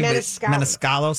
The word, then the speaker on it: Maniscalco: So she plays, I think, Montescuol's wife, or I know I'm saying his name wrong. – I don't know that you're saying it either. – Grant? No, you got it right. If you're close. Meniscalco, I believe. Meniscalco. Sebastian Meniscalco.